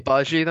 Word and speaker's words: Agbaji: I think the G is silent. I looked this up actually. – Agbaji then? – Agbaji, yeah, I think so.